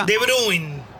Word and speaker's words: De Bruyne: De Bruyne. – Se pronuncia así.